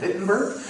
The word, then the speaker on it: Wittenberg: Once again, I love pronouncing Wittenberg like a German, Wittenberg.